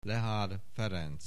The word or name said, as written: Lehár, Ferenc